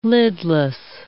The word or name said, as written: lidless